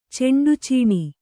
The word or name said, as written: ಚೆಂಡುಚೀಣಿ